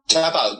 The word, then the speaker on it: Kyar Pauk: Kyar Pauk, yeah.